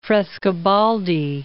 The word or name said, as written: Frescobaldi